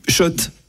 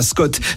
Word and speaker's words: Schott: Oui, Schott et pas Scott. Ça fait des années qu'on prononce mal la marque.